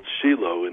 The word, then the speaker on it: Shiloh: But they're all named after the original, which is called Shiloh in Israel. That's the pronunciation in Hebrew.